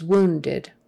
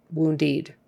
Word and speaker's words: wounded: And that last syllable is kind of like a eh or I, wounded, just not woundied. Wounded.